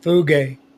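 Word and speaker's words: fuge (φεύγω): When Paul was writing these words to Timothy, he used a Greek word, phego, phego, phego, pronounced fuge.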